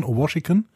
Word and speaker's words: Washington: so wird er ausgesprochen, Washington.